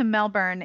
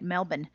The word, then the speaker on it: Melbourne: I almost went from Sydney to Melbourne in Australia. I didn't say that right. Melbourne.